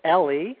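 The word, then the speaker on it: Elie: Well, let me just correct your pronunciation of his first name, Eli.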